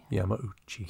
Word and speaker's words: Yamauchi: Hank and Hiroshi, how do you say his Yamauchi? – Name? Yamauchi? – Yamauchi.